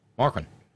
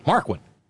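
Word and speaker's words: Markwayne: I'm going to say it that way, Markwin, trying to reduce it to one syllable, Markwin, and Shelley Moore Capito and Katie Britt, the newly minted senator and anointed replacement of Richard Shelby.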